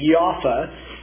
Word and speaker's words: Jaffa: J-A-F-F-A is the way they pronounce it, with a soft J, Jaffa. Joppa, J-O-P-P-A, is the way it's spelled in the New Testament. We always say Joppa, but Jaffa is how they pronounce that.